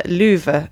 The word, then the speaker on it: Lieuwe: So she tracked down the great great grandson of Vincent Van Gogh's brother Theo, who's called Louvre Van Gogh. I don't know if I pronounced that name right. – I think that's right. – L-I-E-U-W-E. And he's actually also an artist.